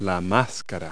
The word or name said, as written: face mask